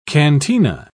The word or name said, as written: cantina